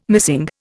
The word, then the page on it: missing: Indian